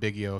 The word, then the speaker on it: Biggio: It's actually pronounced Biggio, if you'll get it correct.